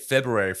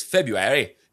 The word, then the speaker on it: February: So what's with the silent R? If you look that far. – I mean, it comes from like a British, the way the Brits say it is February.